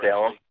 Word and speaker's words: Salem: And, you know, I like the Salam character, if I'm pronouncing that correctly. Salem.